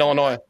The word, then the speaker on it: Illinois: And by the way, just so you know, it's Illinois. – It's okay. Fine. The S is silent. There was a ton of them in Illinois.